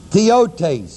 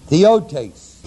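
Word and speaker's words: theiotes: The word eternal is the Greek word Aidios, A-I-D-I-O-S. The word power is the word dunamis, D-U-N-A-M-I-S. Godhead is the Greek word theotes, spelled T-H-E-I-O-T, long E, S, theotes, His eternal, dunamis, and Godhead.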